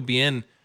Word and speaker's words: Biehn: In this, because I do think Michael Biehn's good. Did I say that right? I'm so sorry. – Yeah, I'm like really eddy about that now. But Michael Biehn's good.